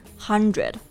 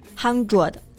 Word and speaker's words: hundred: 这里的字母组合 d r 发 r 的这个音 hundred，不要读成这个 hundred。